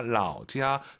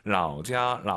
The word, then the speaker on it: 老家: 老家, 老家. 삼성일성은요, 선생님을 떠올리세요. 老师 발음하고 똑같잖아요. 老家. 발음이 똑같은 게 아니고 성조가 똑같은 거죠. 라오자, 라오시.